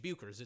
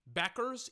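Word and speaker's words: Bueckers: Her name isn't Paige Buchers. It's Paige Beckers, even though it is spelled Paige Buchers.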